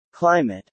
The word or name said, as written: climate